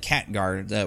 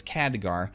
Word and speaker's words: Khadgar: But Khadgar is his name, spelt with a K. It sounded like Khatgar when they were saying it, but it's Khadgar.